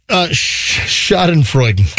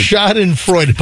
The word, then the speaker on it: Schadenfreude: Shot and farting? – Well... Schadenfreude. – God, never say that right. – Schadenfreude.